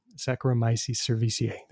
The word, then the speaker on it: Saccharomyces cerevisiae: Oh, I've got, I've got to pronounce it right. Uh, Saccharomycetes, uh, you know, a class of yeast that um, uh, are very common for brewers, um, bakers. Um, Saccharomyces cerevisiae. There we go.